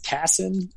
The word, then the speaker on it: Kassan: I pronounce it Kassan. I've heard a whole bunch of different things.